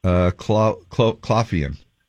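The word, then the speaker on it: Coughlan: Zendaya, Maude Apatow, or Nicola Coughlin? – That's Coughlin. – Coughlin. I said it wrong.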